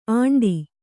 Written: ಆಂಡಿ

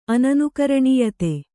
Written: ಅನನುಕರಣೀಯತೆ

